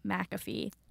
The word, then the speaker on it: McAfee: I always say it wrong with my accent. McAfee.